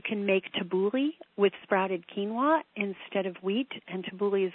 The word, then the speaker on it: quinoa: So a healthy non-gluten diet would be, the best grains would be quinoa, Q-U-I-N-O-A. It's not said the way it's spelled. Everybody says it a little bit different. Some people might say it quinoa, but I think the way that it's derived is quinoa.